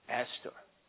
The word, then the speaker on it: Astor: Is that how do you say it? Aston? Astor.